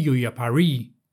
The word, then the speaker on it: Yuyapari: This gulf is a wonderful thing, and dangerous, on account of the very great river that flows into it, which is called the Yuyapari, the last syllable long.